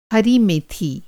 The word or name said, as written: मेथी